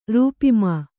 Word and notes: ರೂಪಿಮಾ: rūp'mā

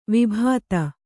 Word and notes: ವಿಭೂತಿ: Kannada

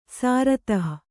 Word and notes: ಸಾರತಃ: sāratah

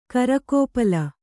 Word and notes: ಕರಕೋಪಲ: karakōpala

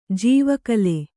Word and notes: ಜೀವಕಾಲ: jīva kāla

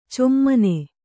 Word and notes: ಛಮ್ಮನೆ: chammane

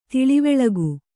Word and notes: ತಿಳಿವಳಿಕೆ: tiḷivaḷike

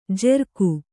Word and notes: ಜೆರ್ಕು: jerku

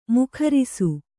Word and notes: ಮುಖರಿಸು: mukharisu

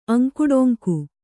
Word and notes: ಅಂಕುಡೊಂಕು: aŋkuḍoŋku

